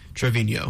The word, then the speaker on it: Trevino: All right, I got Jose Trevino. Is that how you say his name? – Trevino.